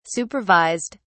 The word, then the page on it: supervised: British English